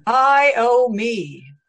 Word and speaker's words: Iommi: One thing I noticed about the TikTok voice, you have to spell it phonetically so it pronounces it correctly. Otherwise, instead of Tony Iommi, it says Tony Iommi. Idiot. – I owe me.